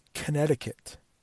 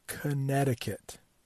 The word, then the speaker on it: Connecticut: The next state, not that much easier to pronounce if you're not a native English speaker, and that is Connecticut. Connecticut. Connecticut.